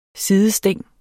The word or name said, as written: sidesting